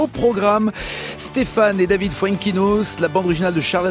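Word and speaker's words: Foenkinos: Déjà, on va dès le début, tout de suite, enlever une vanne que j'ai subie hier, honteusement, parce que j'ai dit Fuenkinos, et vous avez dit, mais non, ça se prononce pas comme ça, les garçons. – Tu le dis parfaitement. – Bah voilà, c'est la bonne prononciation. – Fuenkinos, voilà. Ouais, et pas, et pas fun, je sais pas ce que tu m'avais inventé.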